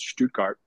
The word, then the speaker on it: Stuttgart: It was always Stuttgart to me. You know, we pronounced it much differently in the south of the USA. There's a lot of German culture, German heritage there, so I knew that properly it was Stuttgart.